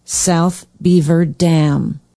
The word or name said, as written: south beaver dam